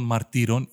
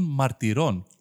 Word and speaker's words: μαρτυρών: Δεν είναι τον αυτό των μαρτύρων, είναι τον αυτό των μαρτυρών. Και είναι των μαρτυρών, είναι όπω είναι των εργατών, των δραστών, των εποπτών. Και τονίζεται στη λίγουσα.